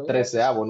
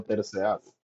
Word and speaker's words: treceavo: No, pero es treceavo, no terceavo.